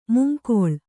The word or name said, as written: ಮುಂಗಾಳು